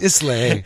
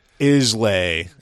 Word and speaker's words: Islay: So they were mispronouncing Islay as Islay, mm-hmm. and it was like... – But that's how it's pronounced in Canada, by the way, so they might have been Canadian. It's Islay. Islay.